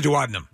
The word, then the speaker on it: duodenum: A duodenum.